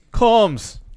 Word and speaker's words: Komes: Why do you say it like that? Combs. Combs. – So it's Combs.